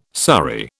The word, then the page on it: surrey: British English